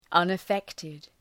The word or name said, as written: unaffected